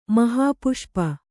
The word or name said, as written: ಮಹಾಪುಷ್ಪ